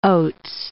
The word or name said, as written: Oates